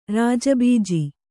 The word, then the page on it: ರಾಜಬೀಜಿ: Kannada